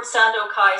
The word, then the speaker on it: Sandokai: And just to review, the word Sandokai is made up of three syllables.